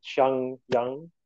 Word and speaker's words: Xinjiang: Yes, and the Xinjiang. Is that how you pronounce it? – Yeah, yes. Region.